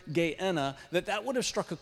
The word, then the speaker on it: Gehenna: The next one is Gehenna, that's often pronounced Gehenna.